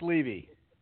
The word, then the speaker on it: Levy: Is it Levy or Levi? – Oh, you're doing good. It's Levy.